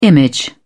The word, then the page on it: image: British English